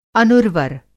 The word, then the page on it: अनुर्वर: Hindi